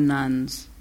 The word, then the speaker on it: nones: N-O-N-E-S. – Yeah. Am I pronouncing it right? – No, you are. Just when you say it, people think you're talking about Catholic nuns.